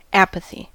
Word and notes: apathy: /ˈæ.pə.θi/

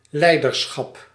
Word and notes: leiderschap: /ˈlɛi̯dərˌsxɑp/

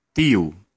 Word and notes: hän: [hæn]

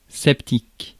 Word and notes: sceptique: France; [sɛp.tik]

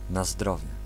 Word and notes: na zdrowie: /na ˈzdrɔvjɛ/

